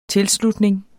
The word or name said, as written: tilslutning